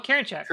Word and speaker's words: Karinchak: James Karinchak. – Oh, Karinchak.